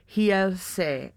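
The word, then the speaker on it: Realce: Yeah, the R's are H's, right? – Yes. – So I guess it'd be Hielse.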